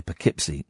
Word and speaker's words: Poughkeepsie: I think it's Poughkeepsie. P- Poughkeepsie. Poughkeepsie. P O U A. P O U G H. Catherine is typing it phonetically on my screen. Just say it. Go on. What is it? It's Poughkeepsie. Is it Poughkeepsie? Mm. Is it really? Did not know that.